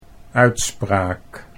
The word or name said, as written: uitspraak